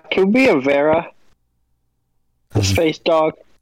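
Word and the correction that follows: varren: Oh, the Varen. – Varen.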